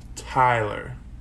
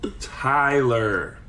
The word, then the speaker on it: Tyler: All right, we're going to finally teach Hannah how to say my name, Tyler, correctly. Here we go. It's Tyler. – Tyler. – Not Tala, it's Tyler.